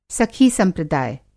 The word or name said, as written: सखी-संप्रदाय